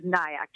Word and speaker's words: Nayak: It's T-A-N-I-Y-A. My last name is Nayak, just like kayak with an N, N-A-Y-A-K.